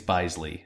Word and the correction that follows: Bisley: not Bisley. Because Bisley sounds a little weird. – Quick note from the corrections department. It's pronounced Steve Bisley.